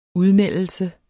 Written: udmeldelse

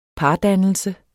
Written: pardannelse

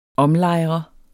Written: omlejre

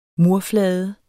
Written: murflade

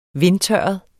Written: vindtørret

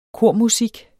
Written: kormusik

